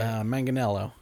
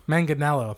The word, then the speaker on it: Manganiello: Is it Joe Manganiello? How do you say it? – Manganiello. – Manganiello.